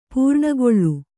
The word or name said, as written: ಪೂರ್ಣಗೊಳ್ಳು